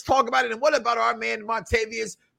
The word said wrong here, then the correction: Vontavious: It's something, but Vontavious Porter.